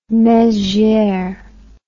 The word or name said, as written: mezieres